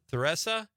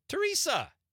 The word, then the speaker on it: Theresa: Why would you pronounce that like that? – Well, how would you say it? – Teresa. – Well, no. – That's how you spell Teresa. – Well, it's a Wisconsin city, so what do you think? – I think they should call it Teresa.